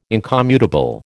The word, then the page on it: incommutable: American English